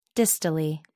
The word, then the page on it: distally: American English